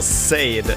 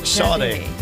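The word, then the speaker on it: Sade: Jag sa Said, det uttalas Shade. Shade heter Shade. Smooth Operator-artisten.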